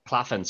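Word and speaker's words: Claflin: Yeah, I kind of went similar with kind of a smaller name in someone that you has used before, Sam Claffia. I think is how you say his name. – Claffin, is it? – Claffin, sorry. Sam Claffin, sorry.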